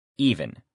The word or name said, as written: even